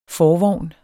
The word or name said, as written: forvogn